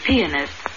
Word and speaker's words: pianist: She was an excellent pianist, playing a Liszt concerto in superb style. – The word pianist. not pianist, like peanut. Accent is on the second syllable.